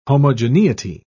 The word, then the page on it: homogeneity: British English